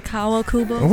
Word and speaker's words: Kawakubo: Put that ham sandwich away from Rei Kawakubo. – You mean Rei Kawakubo? – Whatever. I never pronounced it.